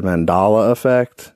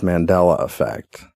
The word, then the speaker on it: Mandela: I said Mandala effect. – Yeah. – It's Mandela effect. – Huh. I thought it was Mandela effect. – Like Nelson Mandela.